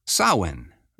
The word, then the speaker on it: Samhain: It's spelled S-A-M-H-A-I-N. It looks like some guy named Sam Hain.